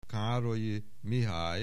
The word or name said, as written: Károlyi, Mihály